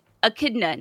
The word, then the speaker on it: echidna: You're right. Echidna, not echidna. Echidna. You're correct.